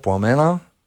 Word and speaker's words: Пламена: Новата песен се казва «Пламенна жена» и е написана за моята съпруга Пламена. – Пламена, да, точно да, така ударението.